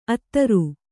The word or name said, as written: ಅದೂರ